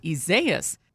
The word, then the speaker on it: Isaias: It says the pronunciation is Isaias, which would have made things a lot easier for meteorologists and the rest of us who have to say the name a lot.